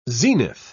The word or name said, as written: zenith